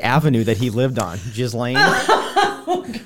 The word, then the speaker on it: Ghislaine: It's all because him and Ghislaine, is that how you pronounce her name? – I think it's Ghislaine.